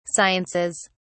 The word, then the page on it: sciences: British English